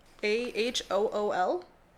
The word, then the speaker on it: Ahool: And I, I think it's pronounced Ahool. A H O O L.